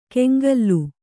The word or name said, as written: ಕೆಂಗಲ್ಲು